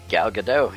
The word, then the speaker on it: Gal Gadot: but is it Gal Gadot? – Gal Gadot, yep, yes sir.